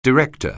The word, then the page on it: director: anglais